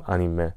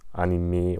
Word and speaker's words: Anime: Also im Original wird es eben Anime ausgesprochen. Und im Deutschen sagt man halt Anime oder Animes teilweise, aber es wird Anime auf Japanisch ausgesprochen.